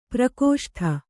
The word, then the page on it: ಪರಾಕಾಷ್ಠ: parākāṣṭha